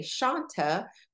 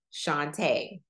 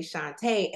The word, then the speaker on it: Chonta: For some reason, I don't know why, but my mom named me Shantae. Spelled the same way, it's just whether or not you have an accent or a long A versus a short sounding A.